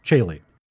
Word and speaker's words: caeli: C A E L I. It's Chile, the road of heaven.